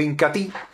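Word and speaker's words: Inca Tea: Y hablando de los productos líderes de la aldea Ramazón, también encontramos mis amigos el Incatí. Se escribe Incatea, pero se pronuncia Incatí.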